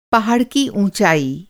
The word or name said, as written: ऊंचाई